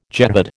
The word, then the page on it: jehad: American English